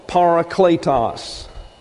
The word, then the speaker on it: Parakletos: Parakletos. P-A-R-A-K-L-E-T-O-S, translated helper.